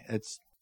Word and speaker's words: Ai: Now Joshua sent men from Jericho to Ai," it's Ai, but it's pronounced I, "which is near Beth Aven and east of Bethel, and told them, 'Go up and spy out the region.'